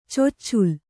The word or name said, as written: ಚೊಚ್ಚುಲ್